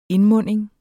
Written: indmunding

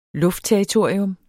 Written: luftterritorium

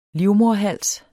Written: livmoderhals

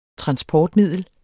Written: transportmiddel

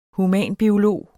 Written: humanbiolog

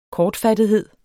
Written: kortfattethed

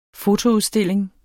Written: fotoudstilling

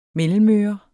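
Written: MELLEMØRE

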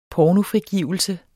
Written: pornofrigivelse

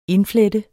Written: indflette

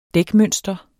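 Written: dækmønster

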